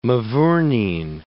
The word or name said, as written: mavourneen